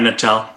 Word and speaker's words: Natal: John has a PhD in organizational psychology from the University of how do I say it? Natal. Natal? – Uh, yeah, Natal.